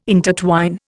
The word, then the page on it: intertwine: Indian